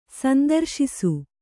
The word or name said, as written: ಸಂದರ್ಶಿಸು